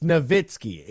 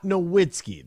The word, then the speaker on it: Nowitzki: It's Nowitzki. It's not Nowitzki. That's not how you pronounce it.